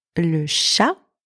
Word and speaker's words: chat: Le chat, don't pronounce the T at the end.